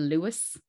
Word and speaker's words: Luas: I have to, because over here in Ireland we have them in Dublin, but they're called the Lewis. So that's why now, and not spelled like L-E-W-I-S, it's spelled like L-U-A-S.